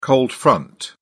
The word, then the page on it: cold front: British English